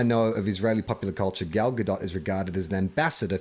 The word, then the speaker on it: Gadot: The Gal Gadot pronunciation. It's Gal Gadot. And we were saying it's Gal Gadot. – Gal Gadot. – Is that right? Yes, it's Gal Gadot.